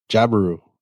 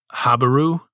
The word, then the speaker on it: Jabiru: How do you say it? – Jabiru. – Not Habiru? Or some other way? – No.